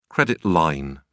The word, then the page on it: credit line: British English